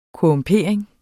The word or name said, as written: korrumpering